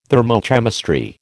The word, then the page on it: thermochemistry: American English